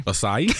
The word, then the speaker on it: acai: I can't say acai. – Acai? You mean acai? – Whatever you say.